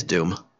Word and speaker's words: Dume: It is Doom. Or Dune. – It's spelled weird, too. – All right. Sorry. Anyway. – Watch it be like spelled normal. Oh, it's Caleb spelled right, but Doom is spelled D-U-M-E.